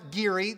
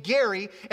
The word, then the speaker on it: Geary: Only they're not going to know that it's pronounced Geary, but they're going to pronounce his name Gary.